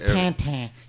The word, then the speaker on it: Tintin: It's Tintin. You love Tintin. That's how you say it in French, right? – Yes. – Tintin. – Yes, that's right.